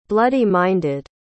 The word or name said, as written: bloody-minded